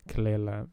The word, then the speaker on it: Kelela: and Submission featuring Danny Brown and Kelela, I think that's how you pronounce her name. Kelela, yeah.